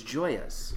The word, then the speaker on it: Joia: Then she said, have you tried, I don't know how to pronounce this, Joyas? – I believe it's Joyas. – Joyas sodas. – J-O-I-A.